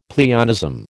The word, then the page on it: pleonasm: American English